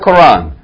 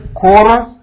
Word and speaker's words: Qur'an: In America we say the Qur'an. It's not Qur'an. It's Qur'an.